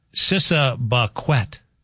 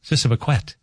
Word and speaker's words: Siseebakwet: It's called S-I-S-E-E-B-A-K-W-E-T. It means sugar in Ojibwe. It's pronounced Sissabaquet. Sissabaquet.